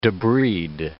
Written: debride

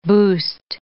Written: boost

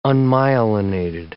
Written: unmyelinated

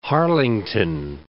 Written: Hayes and Harlington

